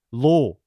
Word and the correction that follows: Io: Io. That's Io.